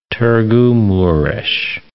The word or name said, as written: Tirgu-Mures